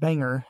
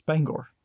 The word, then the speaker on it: Bangor: It's not pronounced Banger after all. It's Bangor. – Yeah, it's pronounced how it's spelled.